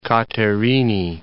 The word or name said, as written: Katerini